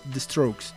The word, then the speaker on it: The Strokes: Les voy a poner una rolita de los Strokes, perdón, mi pendejez al no pronunciar bien, The Strokes.